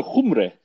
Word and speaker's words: Cymru: It's closer to Cymru. And I'm saying it wrong too, you understand. It's just that mine is close.